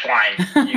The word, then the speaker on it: Schleien: Yeah, Schleien.